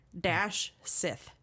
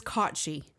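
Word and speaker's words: Cat Sìth: Which, if you look at the spelling of it, it is literally cat, cat-sith, S-I-T-H. And my dumbass read it as Cat Sith the entire time. – So just like people that pronounce Owen as Samhain, kind of basic. – I really whited it down. Yeah. But it is Kotchi.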